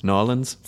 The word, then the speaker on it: New Orleans: I only went to 3 places, Seattle for the film festival, Austin for South By, and then New Orleans to shoot movies. So I didn't go anywhere else. – It's pronounced Nolans.